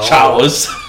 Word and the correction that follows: chaos: Chaos, dear. Chaos. Chaos. You don't pronounce C-H cha in, when it's chaos.